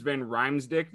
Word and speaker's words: Van Riemsdyk: So I guess looking at star players too for the Flyers, you got James Van Riemsdyk.